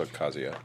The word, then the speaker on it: Ocasio: Alexandria Cortez, Ocasio. Cortez, did I say that right? – I think it's Ocasio.